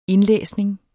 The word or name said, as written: indlæsning